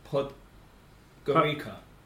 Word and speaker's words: Podgorica: Yeah, Gradski Stadium in Podgorica. – Poland. – Incorrect. – Oh. – Podgorica. P-O-D-G-O-R. I-C-A.